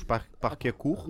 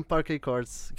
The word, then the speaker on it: Parquet Courts: Isto é um desafio, como é que se pronuncia? Os Parque Acurro? – Não, é mesmo Parque Acords, que eles fizeram um álbum com um álbum e longa duração? – É igual.